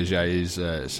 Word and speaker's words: Ajayi: he doesn't have to pronounce Jay Ajayi's second name.